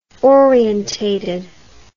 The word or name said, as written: orientated